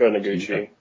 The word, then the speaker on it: Noguchi: Noguchi? – Yeah, Machiko Noguchi.